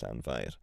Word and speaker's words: Llanfair: So we've got llan. – Llan. – Yes. Fair. – Fair. – So that's llan fair.